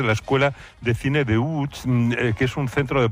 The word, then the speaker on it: Łódź: Aunque se pronuncia Łódź, se escribe Lodz, por si alguien lo busca. – Lodz, sí, Google, sí. No, casi no tiene nada que ver la pronunciación.